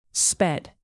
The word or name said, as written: sped